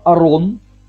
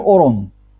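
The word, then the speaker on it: арон (ארון): Например, слово «арон», которое означает «шкаф», обычно пишется через «патах». «Арон». Но когда она написана через «комац», да, это означает «ковчег завета». Да? На ашкеназом это проще простого, потому что мы говорим Арон, и мы говорим Орон.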